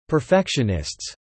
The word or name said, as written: perfectionists